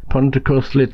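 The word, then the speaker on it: Pontcysyllte: The awe-inspiring Pontecuthlet, that's how you pronounce it,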